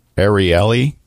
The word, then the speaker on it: Ariely: I'm currently reading Predictably Irrational by Dan, and I'm going to try and pronounce his name Ar- Ari- Ariely, I believe is how you pronounce it.